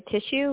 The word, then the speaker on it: Tishue: So if you just search for me, my first and last name, Jessica Tissue, which I'm sure is spelled out, T-I-S-H-U-E, on LinkedIn, feel free to send me a message and connect with me on LinkedIn.